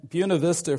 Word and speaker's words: Buena Vista: The school board in Buena Vista, Virginia, and that's the way the natives pronounce it, and since I'm a native of Rockbridge County, I'm pronouncing it that way as well.